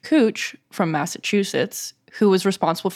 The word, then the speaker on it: Couch: So there's a street in Northwest Portland spelled C-O-U-C-H street. And it took me just weeks after moving here to find out it's pronounced cooch, not couch.